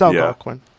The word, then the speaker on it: Algonquin: Sorry, Algonquin. I don't know how to pronounce that, but it's Algonquin.